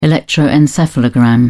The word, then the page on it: electroencephalogram: British English